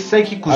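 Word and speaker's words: Saiki Kusuo: Saiki Kusho. Eh, no sé si lo digo mal, la verdad no sé japonés. – Es Saiki Kusho. Así que. Con, así, con ese de. De sarna.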